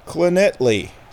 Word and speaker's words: Llanelli: Clonetley is spelled L-L-A... N-E-L-L-I. That's right. Clonetley. Starts with two L's, ends with two L's and an I. Clonetley. L-L-A-N-E-L-L-I. Clonetley. So there's four L's. Four L's in this word, in the name of this town. There are four L's, and there are three different pronunciations of the letter.